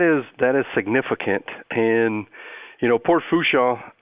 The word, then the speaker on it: Fourchon: Gulf of Mexico are serviced out of Port Fourchon.